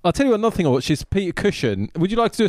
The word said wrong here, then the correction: Cushing: How do you say it? – Cushing. – Cushing.